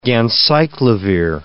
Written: ganciclovir

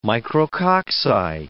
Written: micrococci

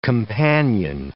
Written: companion

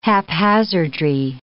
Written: haphazardry

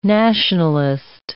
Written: nationalist

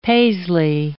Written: paisley

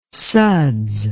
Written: suds

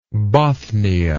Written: Bothnia

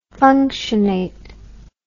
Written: functionate

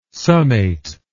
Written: summate

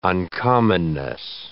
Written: uncommonness

